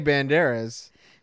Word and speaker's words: Banderas: But that's how I always just say Banderas. – No, you have to do you have to roll the R.